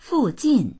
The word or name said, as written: fu4jin4